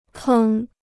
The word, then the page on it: 坑: kēng